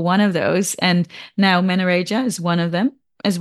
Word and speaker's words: menorrhagia: And you just mentioned, because you said when you were younger, you had, did you say menorrhagia? Did I pronounce that right? – Menorrhagia, so it's just like bleeding. So it's, yeah, it's like excessive bleeding. So it's meant to be like, you know, heavy menstrual bleeding, but when it goes like beyond the time frame for your menstrual cycle, when it's really heavy, when it's going on for weeks, when it's going on for months, you know, that's when you get diagnosed with menorrhagia. Yeah. – Okay. Sorry, I pronounced it completely wrong.